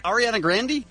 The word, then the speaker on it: Ariana Grande: Ariana Grande? – That's not how you say her name. Don't be ridiculous. – Oh, okay. – Ariana Grande? – I'm sorry.